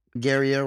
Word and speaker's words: Guerrier: Is it like Gruyere? – No, not quite. It's Guerrier Roser.